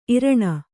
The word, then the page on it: ಈರಣ: kannada